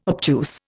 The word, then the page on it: obtuse: Indian